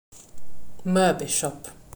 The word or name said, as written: Merbishop